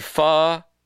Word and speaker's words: pho: Pho Keene Great Restaurant. As you are no doubt aware, Vietnam's national dish, a broth and noodle soup, is pronounced pho, which the city officials in Keene say makes the restaurant's name inappropriate.